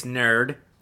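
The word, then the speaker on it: N.E.R.D.: And if you guys are wondering, it's Nerd. That's right. – It's NERD. That's how you pronounce that, sir.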